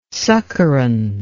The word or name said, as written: succorance